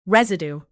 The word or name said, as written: residue